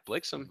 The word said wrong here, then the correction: Blitzen: Yeah, Donder and Blitzen are actually the reindeer's names too, meaning thunder and lightning. – So we've been saying it wrong.